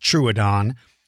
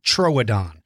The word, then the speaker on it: Troodon: Now, I pronounce it Troodon. Some people pronounce it Troodon.